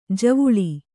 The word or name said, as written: ಜೀವಲ